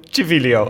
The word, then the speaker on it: Civiglio: En de Civiglio. – Civilio. – Civilio. oh, ah, zo close, zo close met het goed uitspreken.